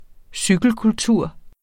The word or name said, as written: cykelkultur